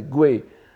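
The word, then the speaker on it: Wei (潙): La prononciation ancienne de ce caractère était Gui, mais maintenant tout le monde prononce Wei en Chine aussi.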